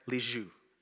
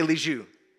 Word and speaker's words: Lisieux: Am I saying that right? Lisieux. Okay? Everybody say Lisieux. Okay, now you can all speak French. Okay, so Teresa of Lisieux.